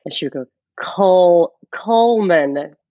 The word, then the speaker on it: Cullman: So I know you grew up in Cullman, Alabama. Am I saying it right? – You are.